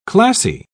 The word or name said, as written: classy